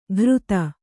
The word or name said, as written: ಘೃತ